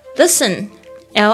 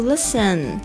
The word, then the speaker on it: listen: Listen，L I S T E N，特别注意一下，不要念成 listen，OK，这边的 S T E N 呢，通常都是发那个 t 是不发音的，silent。 OK，Earth listen，listen 就是听、倾听的意思。 The earth has music for those who listen。